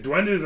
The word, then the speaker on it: Duende: Oh, if I remember correctly, it's spelled D-U-E... N-D-E.